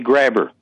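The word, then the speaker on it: Grabher: Well, that's right because, I mean, when I pronounce my name, I just say grabber. – Grabber. Yeah. – The H is silent.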